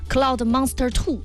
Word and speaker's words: Cloud Monster 2: 而且今天又给帮大家重新谈到了新品，就是昂跑他们家的这个 Cloud Monster 2 啊，不知道这个发音对不对啊。